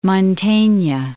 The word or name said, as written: Mantegna